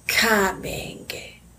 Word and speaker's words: Kamenge: And that is Kamenge. So together is Fatima Kamenge. – So you you put emphasis on the ka menge. – Yes, and the me, and then ge. Yes.